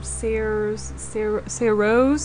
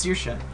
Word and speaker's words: Saoirse: Sarah Rose? – Saoirse. It's Saoirse.